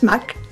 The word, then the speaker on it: Magg: Wenn ich für eine Sache eine Ahnung habe und die gut machen kann, dann habe ich es Mack. Das schreibt man M-A-G-G.